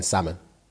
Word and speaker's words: salmon: Pronouncing the L in salmon.